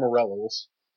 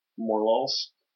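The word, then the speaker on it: Morelull: I like more Morellals. – Morlals? – Morellals. – Is that how it's supposed to be pronounced? – It's spelled Morel, like the mushroom, and then with an extra UL.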